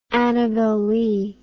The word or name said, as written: annabel lee